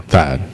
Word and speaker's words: Thad: Thank you, Tad. Tad. Oh, sorry. It's Thad? Thad. I think I said it right now. Thad.